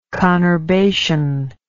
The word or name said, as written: conurbation